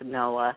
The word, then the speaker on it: Knowa: He spells it K-N-O-W-A, but it is pronounced Noah.